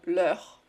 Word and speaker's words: leur: Tady to EU má dva typy výslovnosti ve francouzštině. Jednou je to jako němý Ečko, říkáš jako E. Jednou je to jako takový Ečko, otevřený s našpulenou pusou. Není to jako E, ale E. Leur. Vidíš tu polohu pusy jinou? Je strašně důležité to rozlišovat.